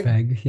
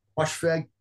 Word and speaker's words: Moshfegh: Moshfegh, yeah. – Moshfegh.